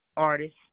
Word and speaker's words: Artis: My name is Tanya Artis.